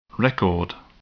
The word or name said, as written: record